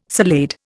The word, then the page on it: salade: Indian